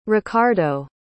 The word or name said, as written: Ricardo